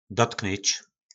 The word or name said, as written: dotknyc